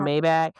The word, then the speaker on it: Maybach: Is that how you say it? Maybach? Yeah. She's in a Maybach.